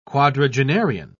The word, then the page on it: quadragenarian: British English